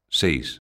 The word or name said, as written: seis